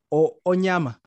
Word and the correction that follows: Onyango: Yeah, Onyama, yeah. – Yes. – Onyango. – Onyango, my bad.